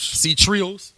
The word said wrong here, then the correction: C-Trois: Trois. – C-Trois. C-Trois. – You know, like Menage Trois.